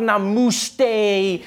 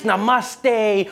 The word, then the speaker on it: namaste: People were coming on like, dude, it's not namuste, it's namaste.